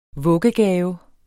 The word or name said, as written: vuggegave